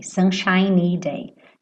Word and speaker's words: sunshiny: Sunshine termina com E e o E é mudo, seria o brilho do sol, tá? E sunshiny tá fazendo adjetivo. Então, esse Y tem que aparecer. Sunshiny day. Sunshiny day.